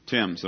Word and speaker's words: Thames: Did I say that right? Thames, okay. I qualified it here. Going to go throw himself down in the River Thames. It's not spelled like that.